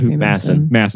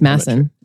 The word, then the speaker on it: Masson: I forget how to pronounce his name, but Masson. – Thank you. – Or Mason. – I think it's Masson. Two S's. – Masson. – Yeah. So pronounced like the Orson Welles wine. Okay, great. Like Paul Masson wine.